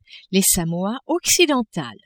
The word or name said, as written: Samoa occidentales